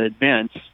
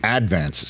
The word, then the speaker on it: Advance: Well, if you're really from Staten Island, you'd call it the Advance. Then you put the accent on the wrong syllable.